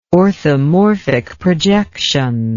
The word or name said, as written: orthomorphic projection